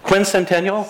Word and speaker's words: sesquicentennial: We had this wonderful thing called the sesquicentennial. Did I say that right? – No. – No, I did not. What? Quincentennial? – Sesquicentennial.